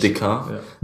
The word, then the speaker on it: Descartes: Oder spricht man den so aus? – Descartes, glaube ich. – Descartes, ja. Genau.